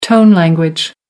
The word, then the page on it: tone language: American English